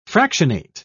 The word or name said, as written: fractionate